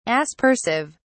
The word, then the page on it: aspersive: British English